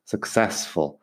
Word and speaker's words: successful: successful significa que tiene esta característica y mucho. Successful. Fíjate en la pronunciación de successful, que no hay sex en ninguna parte. Sex es otra cosa. Successful, exitoso.